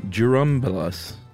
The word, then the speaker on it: Churumbelas: In fact, there is a Spanish folk song by the Churumbelas, suggestion on how to pronounce it, Churumbelas. – Well, is it a right. DJ? – It's a CH pronounced like a JU, he says. Jerome Bellis.